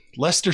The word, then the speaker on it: Leicester: Leicester City. Said it right that time. How about that? Leicester City.